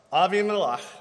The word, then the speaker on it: Abimelech: And the king of Gerar, Abimelech, I know it's Abimelech, but I got into the Jewish commentator, so all week long I've been hearing Abimelech. And he took her for himself.